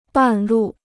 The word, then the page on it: 半路: bàn lù